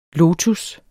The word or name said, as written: lotus